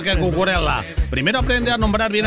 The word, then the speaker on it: Cucurella: No, es Cucurella, no Cucurella. – Claro. – Es Cucurella. Cucurella, sí.